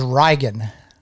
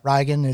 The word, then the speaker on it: Riagan: My real name is Reigan. – Like, how would you spell that? – R-I-A-G-A-N. – Okay. – Reigan. It's the Gaelic Irish pronunciation of Reagan.